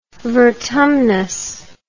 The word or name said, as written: vertumnus